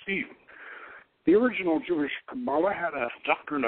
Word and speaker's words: Kabbalah: K-A-B-B-A-L-A-H, pronounced Qabalah, refers to a system of Jewish mysticism developed in medieval Southern Europe. C-A-P-A-L-A is a Christian version of it,